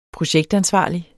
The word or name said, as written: projektansvarlig